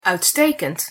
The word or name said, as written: uitstekend